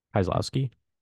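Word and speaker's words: Kieślowski: How do you say his name? – Kieslowski.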